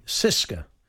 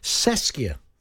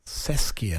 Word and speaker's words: CSKA: Some people say Cisca. – Yes. – But Alan Pards, he said it's pronounced Seskia. – Seskia.